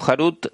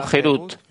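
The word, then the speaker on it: Gerut (חרות): No se pronuncia como Harut, sino Gerut, libertad, pues ha llegado la libertad del ángel de la muerte.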